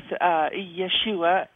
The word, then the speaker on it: Yeshua: I've been talking with Yeshua, as his Hebrew name is pronounced.